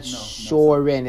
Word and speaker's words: Shorin: But he adds Kyokushin components to it because Shuren Ryu, is that the one that Francois Presereau? Shorin. It's a different. It's pronounced different.